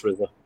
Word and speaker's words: RZA: It's pronounced RZA.